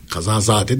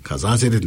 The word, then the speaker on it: kazazede: Kazazade denmez. Kazazede denir. Bilmiyorsan telaffuz etme.